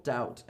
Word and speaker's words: doubt: It is also generally silent before the T at the end of a root word. Subtle, doubt, and debt.